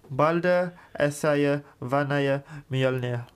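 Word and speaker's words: Mjölnir: Mjolnir. – Yes, Mjolnir.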